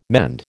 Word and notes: mend: American English

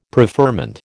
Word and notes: preferment: American English

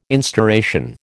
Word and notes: instauration: American English

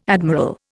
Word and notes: admiral: Indian

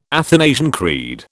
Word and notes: Athanasian Creed: British English

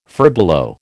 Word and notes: furbelow: American English

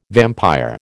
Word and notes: vampire: American English

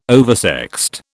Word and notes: oversexed: British English

